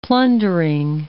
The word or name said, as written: plundering